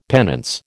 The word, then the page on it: penance: American English